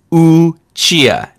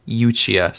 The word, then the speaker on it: Uchiha: It's Naruto, not Naruto. It's Madara, not Madara, and it's Uchiha, not Uchiha. So, good job.